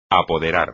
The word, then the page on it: apoderar: espagnol